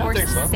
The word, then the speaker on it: Coursera: I think uh, my greatest motivation, like, I, I that's interesting because I'm taking a class like through Coursera. Is that how you say it? I don't even know how to say it. – Yeah, Coursera.